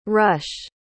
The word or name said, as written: rushe